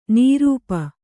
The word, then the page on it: ನೃಪ: kannada